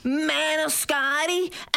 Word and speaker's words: Maniscalco: Maniscalco. Think of like Maniscalco and also like a scalper at a basketball game.